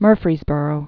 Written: Murfreesboro

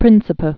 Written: Sao Tome and Principe

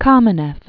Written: Lev Borisovich Kamenev

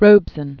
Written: Paul Bustill Robeson

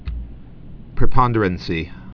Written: preponderancy

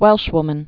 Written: Welshwoman

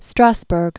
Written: Lee Strasberg

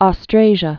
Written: Austrasia

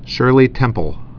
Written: Shirley Temple

